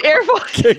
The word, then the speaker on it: AirPods: No, it's AirPods.